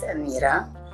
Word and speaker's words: Amira: It's Amira.